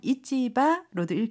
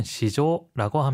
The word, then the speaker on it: 市場: 그런데 이 한자어는요. 지금처럼 이치바로도 읽히고요. 음을 읽어서 시조라고 읽히기도 해요. 선생님, 이치바, 시조 어떤 차이가 있을까요? – 하이. 상품이나 물건을 거래하는 시장은 이치바. 추상적인 경제적 시장이나 개념은 시조라고 합니다.